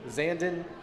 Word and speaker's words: Zandon: But like Zandavoo? – Zandon, just spelled like Brandon, but with a Z. Z-A-N-D-O-N.